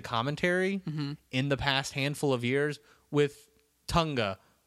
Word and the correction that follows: Otunga: with Otunga. I don't know why I cut off that first syllable.